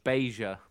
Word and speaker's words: Basia: B-A-S-I-A, Beja.